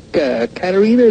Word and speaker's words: Katerina: And I have a question for Katerina. Is that how you say it? – Yes.